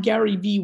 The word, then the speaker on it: GaryVee: I'm Gary V with two silent E's.